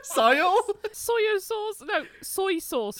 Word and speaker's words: soy sauce: That was the only change that we really made, and we use gluten-free soya sauce as opposed to soy sauce. – Sorry, I always say it like that. – Soy sauce. – I always say soya or soy. – That's the Swedish word, soya.